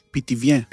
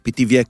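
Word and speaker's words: Pithiviers: איך מבטאים? פיטיביה? – פיטיביה. – פיטיביה. – פיטיביה, כן.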